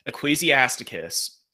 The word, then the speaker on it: Ecclesiasticus: You said Ecclesiasticus. That's different than Ecclesiastes, correct? – Yes. Yes, it is. Ecclesiasticus is going to be a very well-respected Jewish text.